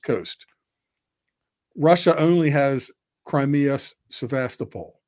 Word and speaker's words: Sevastopol: They pronounce it the different way than we do. We say Sevastopol.